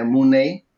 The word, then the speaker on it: Mooney: Ε, ε, έχουν και τον άλλον, τον Daniel ε, Mooney, ε, ε, που γράφει το επιθετικό του Σαν Money με ένα έξτρα O και δεν ξέρω γιατί δεν το προφέρει Ε, Εντάξει, είναι...